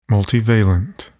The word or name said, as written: multivalent